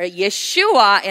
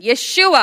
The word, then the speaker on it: Yeshua: It was Jesus, or Yeshua in his original pronunciation. Yeshua, all together.